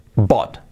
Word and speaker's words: bought: La pronunciación de bought, igual que thought, recordad el pasado de pensar, el participio, pues aquí, bought. Buy, comprar, y en pasado, y en participio, bought.